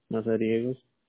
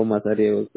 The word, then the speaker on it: Mazariegos: Isidro Ramírez Mazariegos. – Okay. ¿Cómo se escribe el otro apellido? – ¿Ramírez o Mazariegos? Con Mazariegos. – M-A-Z-A-R-I-E, la G de gato, O-S.